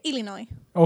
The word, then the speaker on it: Illinois: Είναι σαν το Iceland, που είναι Island. – Ισχύει πάνω κάτω, ναι. – Έχει το S τέρμα, αλλά προφέρεται Illinois.